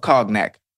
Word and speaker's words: cognac: Okay, you said cognac, but it's spelled C-O-G-N-A. Why not a Y? Because you're saying yak. Cognac. So it should be C-O-N-Y-A-C.